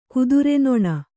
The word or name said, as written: ಕುದುರೆನೊಣ